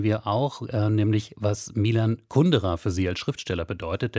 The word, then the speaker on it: Kundera: Kundera ist richtig.